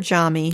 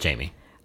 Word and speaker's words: Jami: And we should be clear, we call her Jami. – Her name is Jamie. – It is spelled J-A-M-I, which we just think is fun to say as Jami.